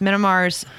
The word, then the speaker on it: Myanmar: imposes sanctions against Myanmar's state-owned oil enterprise. – And you pronounced it right. It is Myanmar. That's pretty good.